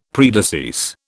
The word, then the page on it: predecease: British English